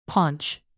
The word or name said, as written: paunch